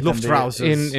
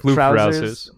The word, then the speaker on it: Luftrausers: Luff Trousers. Not sure if you pronounce that differently. – Luftrausers. – Instead of Luftrausers.